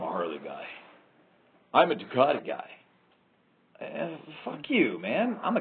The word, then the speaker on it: Ducati: You have them in one building, and then you introduce the Ducatinisti, you know, which is the all like Ducati rhymes with Bukkake.